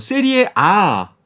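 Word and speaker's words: Serie A: 이탈리아 리그를 이제 방, 금 전에 이제 박사나 위원도 표현하셨지만 세리에 A라고 쓰는데 근데 읽을 때는 어 세리에 A 라고 읽으시더라고요. – 네, 이게 이탈리아 말이니까 그 나라에서 이제 불리는 발음대로 얘기를 해줘야 되고요. 음... 어, 거기는 이제 영어가 아니니까 네, 라틴어니까 이제 아라고 오...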